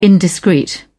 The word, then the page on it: indiscreet: British English